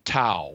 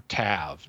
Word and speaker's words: Tav: Yeah, like the last letter of the Hebrew alphabet, Tau or Tav, depending on how you want to pronounce it.